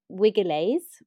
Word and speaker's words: vigiles: So their public firefighting service was called vigiles, is how they would pronounce it in Latin. But we would say that's where we get the word vigil from, because it starts with a V. But they said a soft V, so it's vigiles.